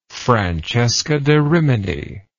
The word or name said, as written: francesca da rimini